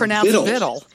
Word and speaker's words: victual: At the end of the book, you'll see victual, V-I-C-T-U-A-L. – Mm-hmm. – And it's pronounced vittle. – Correctly, it's pronounced vittle like Granny on the old TV show Beverly Hillbillies.